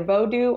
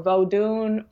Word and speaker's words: Vodou: So vodoo, we spell it in Haitian voodoo, we spell it V-O-D-O-U. In places in Africa, it would be spelled with an N at the end. And some people pronounce the N and some people don't pronounce the N. So it could be either vodoo or vodun or voodon. You'll hear that often, but it's used interchangeably.